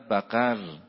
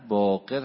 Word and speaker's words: باقر: این اومد امام باقر رو جلوی شاگردانش ضایع کنه، نعوذ بالله نعوذ بالله نعوذ بالله، گفت انت بقر، امام باقر. چه باید میکردن؟ هیچی ها قیمه قیمش میکردن. حضرت با یه روی خوشی گفتن که برادر اشتباه تلفظ کردی، من باقر هستم.